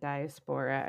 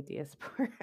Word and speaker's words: diaspora: I apologize, I mispronounced that diaspora. I don't know why I was saying diaspora.